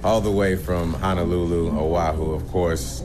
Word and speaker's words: Oahu: He was staying in Oahu. Is that how you say it? I've never been to Hawaii, period. – Oahu? – Oahu.